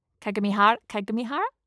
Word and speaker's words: Kagamihara: Kagamihara? – Kagamihara, yeah.